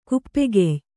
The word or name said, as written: ಕುಪ್ಪೆಗೆಯ್